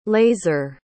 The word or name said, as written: lazer